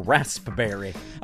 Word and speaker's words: raspberry: The P is always The P is silent. – Raspberry? – Yeah. – Yeah. – It's got a P in there. – It's a raspy berry. – It's a raspberry.